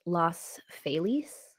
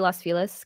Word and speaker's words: Los Feliz: Now, apparently it's supposed to be pronounced Los Feliz. – Mm-hmm. – That's what the internet says. I'm just going to say Los Feliz because that seems to be like the more English pronunciation.